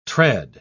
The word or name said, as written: tread